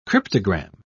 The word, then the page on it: cryptogam: British English